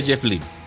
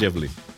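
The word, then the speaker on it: Jeff Lynne: Pero creo que sí se pronuncia Jeff Lynn. Creo que sí es Jeff Lynn.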